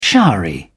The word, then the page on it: showery: British English